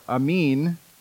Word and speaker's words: amen: The phrase amen, or amin, as it's pronounced, simply means we agree.